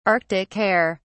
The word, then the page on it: arctic hare: British English